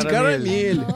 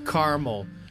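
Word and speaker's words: caramel: Есть карамель, есть кармел. Чаще всего, откуда я говорю кармел. – А в чем разница? – Карамел, кармел. Два слога или три? Есть два произношения на английском. Зависит от региона.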